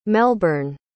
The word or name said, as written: melburn